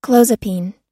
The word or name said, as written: clozapine